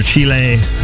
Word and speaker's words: Chile: They, of course, are out of Chile, or Chile, I think, in Spanish class is how they told us to pronounce it.